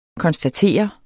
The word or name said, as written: konstatere